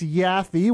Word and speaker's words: Yaffee: It's Yaffee. Y-A-F-F-E. – It's not Yappy? – No. Stop it in there. – Rhymes with Taffy.